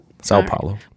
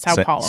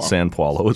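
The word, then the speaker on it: São Paulo: Sao Paulo. – San Paulo is what you say. Sao Paulo is correct.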